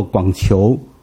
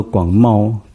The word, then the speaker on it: 广袤: 还有一个字，我也是几十年都很自然把它读作，再讲到一个广大的一个情况，就是读作广球，因为那个字其实我眼睛很好，我明明知道那个字里面不是球那个字，那个字应该读作广袤，听过知道这个词吗？你连这个词都不知道？一个这样，有没有两？没有没有，就是一个枪矛的矛，下面再一个像这样的字，那个字多茂，广茂。